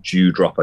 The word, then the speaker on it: Doudrop: speaking of the women's division, Sean Ross Sapp of Fightful tweeted WWE had Doudrop penciled in as Doudrop, pronounced Dewdrop, I should say, penciled in as Piper Niven's new character name at one point.